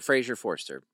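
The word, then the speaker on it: Fraser Forster: Frazier Forrester. – Right. Okay. What is it, Nathan Forrester? – You added a syllable.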